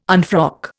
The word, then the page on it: unfrock: Indian